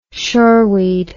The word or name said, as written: shoreweed